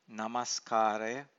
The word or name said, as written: namskaraya